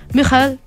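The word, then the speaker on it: Michael: Mijael, ¿no? Sí. Schumacher. Aquí nosotros decimos Schumacher, pero yo he escuchado que dicen Mijael Schumacher. – Ah, Así se pronuncia.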